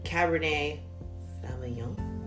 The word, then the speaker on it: Cabernet Sauvignon: So this wine is made with 100% Texas grapes, 100% Cabernet Sauvignon. Is that how you say it? – Yeah. Yes.